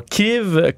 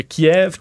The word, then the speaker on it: Kyiv: Donc, Kiev, K-Y-I-V. Euh, donc, c'est euh, la façon ukrainienne de prononcer le nom de la capitale plutôt que Kiev, qui est la version euh, russe.